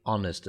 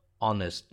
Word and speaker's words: honest: Here the word honest is spelled H-O-N-E-S-T, but when we're pronouncing it, we drop the H. And we say honest as if we're putting the stress on the O. So honest, not honest. Okay, so H-O-N-E-S-T.